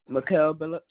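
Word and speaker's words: Mikal Billups: Mikael Billups.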